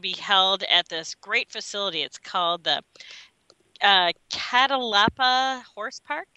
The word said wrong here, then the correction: Catalpa: And I probably just completely mispronounced that name, but it's Catalpa Horse Park.